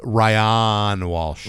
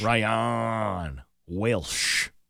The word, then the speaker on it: Welsh: I believe it's pronounced Ryan Walsh. – Ryan Welsh. – Yes. – His name is Welsh.